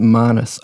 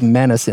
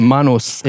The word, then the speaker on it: Manus: It's pronounced Manus. I've got to tell you. Now, everyone says Manus in the Australian media, but I've lived in PNG as a kid. It's Manus. It's Manus Island.